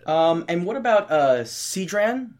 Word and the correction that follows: Círdan: I'm probably saying it wrong. – Oh, Círdan? – Oh, Círdan, sorry. Círdan.